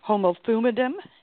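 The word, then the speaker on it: homophumidum (ὁμοθυμαδόν): And you have a word in there that I can't find anywhere, so I'm not sure if I'm pronouncing it or not right, but it's homophumidum. – That's how you pronounce it.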